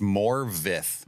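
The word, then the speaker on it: Morfydd: I always feel like I'm pronouncing her name wrong. – It's Morveth. – Morveth Clark. – It's not spelled that way at all.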